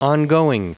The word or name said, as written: ongoing